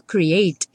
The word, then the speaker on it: create: O verbo create, ele já termina na letra E, só que essa letra E não é pronunciada. Então, eu falo create e termino no T.